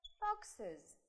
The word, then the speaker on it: foxes: Добавляем окончание ES, получаем foxes. Foxes. Foxes, dishes, benches, dresses. Везде у нас будет произноситься с вами is.